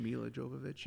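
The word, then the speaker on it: Milla Jovovich: I think Mia Jovovich is how you say her name? – Mila Jovovich, yeah.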